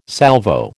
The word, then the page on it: Salvo: American English